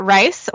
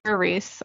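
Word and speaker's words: Rhys: It's R-H-Y-S. – Either I think you could pronounce that Rice or Reese. I've heard it both ways.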